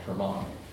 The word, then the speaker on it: Hermon: Now, Caesarea Philippi is at the base of the tallest mountain in Israel called Mount Hermon, or you would say Mount Hermon, right? They always put the accent on the last syllable.